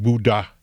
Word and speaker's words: Buddha: I mean, why do you think the word Buddha has an H there? – Mm. – We don't pronounce it in English, but that's an aspirated voiced consonant that doesn't exist in English.